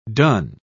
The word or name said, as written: donne